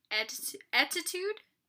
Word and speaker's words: Ettitude: Well, I found an online store, and it's called Etitude. It's spelled Ettitude.